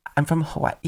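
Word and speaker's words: Hawaii (Hawaiʻi): I'm from Hawaii. – There's an apostrophe. There's a wait. That's how you pronounce it, my guy.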